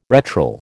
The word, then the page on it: retral: American English